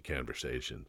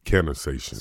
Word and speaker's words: Cannasations: Conversation. Yeah. Am I saying it right? Conversation. Sorry, dude. You had it right the first time.